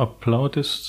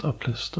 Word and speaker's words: Aplist: Und äh, Joachim hat dann auch festgelegt, dass wir das Deutsch aussprechen und dass der zu Applaudissement zugehörige heißt Aplist, also nicht etwa Applaudist, sondern der heißt Aplist, aber das nur so am Rande.